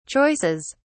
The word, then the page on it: choices: British English